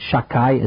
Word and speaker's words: Shakai: We don't pronounce it properly because it's one of the names of God. We pronounce it Shakai, as if the second letter was a kuf, and it means Almighty.